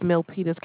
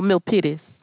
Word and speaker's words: Milpitas: Okay, the city and state is Milpitas, California. – Okay. Any way you can spell that? I've never heard of that. – It's spelled M as in Mary, ILPITAS. – Militant? – No, Milpitas.